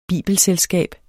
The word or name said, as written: bibelselskab